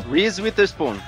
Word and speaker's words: Reese Witherspoon: Reese Witherspoon. Isso, que, que pronúncia.